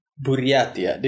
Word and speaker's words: Buryatia: You're from Buryatia. Did I say it correctly? – Yes, it is correct.